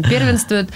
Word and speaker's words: первенствует: И первенствует в нем, первенствует, господи, что у меня с ударением, первенствует в нем все-таки текст, а потом музыка.